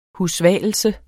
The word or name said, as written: husvalelse